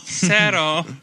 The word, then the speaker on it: cero: Sarah. That's how you say zero in Spanish. – Sarah. – How do you spell it? C-E-R-O? – No, Sarah.